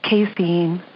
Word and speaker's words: casein: Many, many years ago, there were all these cheeses and everybody thought they were vegan and they had that little, how do you say it? Casein. I never know how to say that. – Casein, yeah.